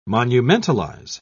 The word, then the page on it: monumentalize: British English